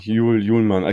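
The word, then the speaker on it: Hjulmand: Juhlmann. – Ich habe vorhin noch mal so ein paar Quellen, ja, Juhlmann. Ich glaube, das D spricht man nicht mit aus, das H auch nicht, ne? Vorne so, Juhlmann.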